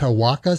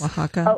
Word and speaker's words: Oaxaca: C-H-I-C-H-I. – Chihuahua. – C-H-U-C-H-U. – Yeah. Chihuahua. Chihuahua. Chihuahua. Like, what is Oaxaca spelled with? How do you say, with an O? O-X? – Oaxaca.